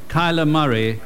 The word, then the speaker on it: Kyler Murray: The Heisman Trophy is awarded to Kyler Murray of Oklahoma University. – So you heard the guy say it right there.